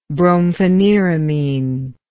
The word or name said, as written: brompheniramine